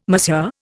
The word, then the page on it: Monsieur: Indian